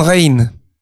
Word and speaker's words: Rijn: Il s'appelait Rembrandt van Rijn. – Ah. – En van, V-A-N, et R-I-J-N, qui se prononce R-I-J-N.